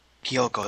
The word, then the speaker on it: Kyouko: Kyoko. I think you say her name. K-Y-O-U-K-O. Kyoko? – What? – Kyoko? K-Y-O-U-K-O. – Kyoko? – Kyoko, that's how you say it.